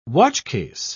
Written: watchcase

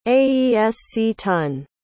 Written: aesctun

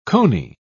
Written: cony

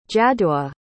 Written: jaddua

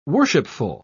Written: worshipful

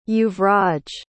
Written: yuvraj

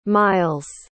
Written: miles

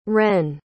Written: ren

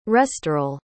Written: restoril

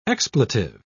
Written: expletive

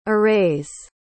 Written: arrays

